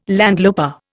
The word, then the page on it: landloper: Indian